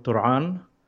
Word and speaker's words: Turan: I live in northern Palestine in a small village called Turan. That's how it's pronounced in Arabic.